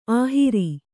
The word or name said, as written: ಅಹೇರು